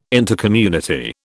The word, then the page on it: Intercommunity: British English